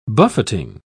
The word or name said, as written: buffeting